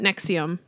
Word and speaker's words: NXIVM: So you guys, I had to ask Shiloh how you said this, because it's spelled N-X-I-V-M, which fuck these guys. Like if I didn't already hate them, the fact that you spelled it like this and then it's pronounced Nexium is like next level marketing bullshit that I hate.